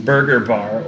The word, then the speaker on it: BRGR Bar: So, so we are at Burger Bar or Burger Bar, if you're to pronounce it phonetically, because it's BRGR Bar in Portsmouth, New Hampshire.